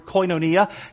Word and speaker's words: koinonia: It's worth noting here that the Greek term most often used for fellowship in the New Testament is the word koinonia. K-O-I-N-O-N-I-A.